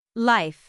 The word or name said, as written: life